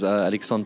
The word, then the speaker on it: Alexandre: So my name is Alexandre Poels, or just Alex is fine. Okay. It's the French version of Alexander, but no one can ever pronounce the R-E at the end.